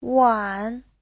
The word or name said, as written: wan3